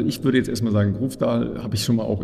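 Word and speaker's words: Grøvdal: Ich glaube, Grofdal spricht man so aus, richtig? – Grofdal, ja.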